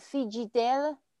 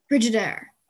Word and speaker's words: Frigidaire: Is this a brand is called Fiji Dale? Dale. Fiji, there. I'm not sure. Is that this? A Frigidaire. – Frigidaire. – A Frigidaire. Okay, 我的洗衣机是 Frigidaire.